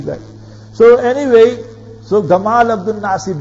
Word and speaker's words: Gamal: If you read about Egypt and the history of Egypt, there was a ruler, the Egyptians, they pronounced the jim a bit different, you know. When they read Quran, it's excellent, but in their colloquial when they speak, his name was Jamal Abdul Nasser. But when they pronounce it and they write it, they write Gamal, you understand? Gamal Abdul Nasser.